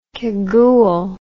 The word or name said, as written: cagoule